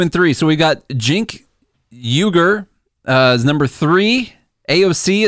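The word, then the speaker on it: Cenk Uygur: It's Jink. Sorry. Jink Uyghur. – Okay. – Jink Uyghur. Yeah. – Okay. – From him. That's from him, by the way. Verified source. – Oh, verified source. He says how to pronounce it. – He says, my name is pronounced Jink, like short for Jenkins. Uyghur. Uyghur.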